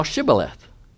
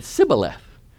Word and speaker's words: Shibboleth: If he said no, then they would say to him, "Say now Shibboleth." But he said Sibboleth, for he could not pronounce it correctly.